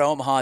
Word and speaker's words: Omaha: And then it was great to see Tony Vitello yell out Omaha at the end, Ben going, no. It's more three syllables. It's in the diaphragm.